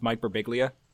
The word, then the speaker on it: Birbiglia: I also remember, like, Mike Birbiglia did it in his first special where he was like, where he said his name wrong a bunch of times so that people knew it was pronounced Mike Birbiglia.